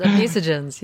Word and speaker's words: obesogens: But something that caught my attention was this word obesogenes. I don't know if I'm pronouncing this right. – Yes, obesogens, yes.